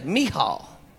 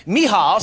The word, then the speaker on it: Michal: Now, as the ark of the Lord came into the city of David, I never knew how to pronounce this woman's name. But here y'all go. Y'all going to learn someday. Michal. Michal,